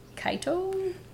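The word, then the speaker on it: Chaeto: and large clump of the macroalgae Keto? – Cheeto? Different people pronounce it different ways, keto or I Cheeto.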